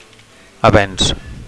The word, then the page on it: avenc: Catalan